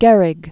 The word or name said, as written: Gehrig